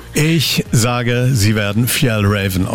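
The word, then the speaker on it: Fjällräven: Ich bin Franziska Köhler von der schwedischen Outdoor-Marke und es wird richtig ausgesprochen Fjell Raven.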